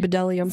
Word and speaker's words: bdellium: Bdellium. – Whatever. Did you look up how to say that? – No, but it starts with a B. – Bdellium. – Silent B. – The B is silent.